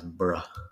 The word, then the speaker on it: Edinburgh: I mean, it's spelled like Edinburgh. – What? – The last part, B-U-R-G-H, I think, something like that. – Yeah. – Let's pronounce Burrah.